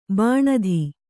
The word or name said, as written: ಬಿಣ್ಣಿತು